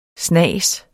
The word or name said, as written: snas